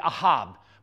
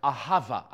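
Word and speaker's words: ahava (אהבה): So here's my favorite, the Hebrew word, ahava. Everybody say ahava. In the Strong's Concordance, it will be ahab, but that's how the Jews pronounce it, ahava.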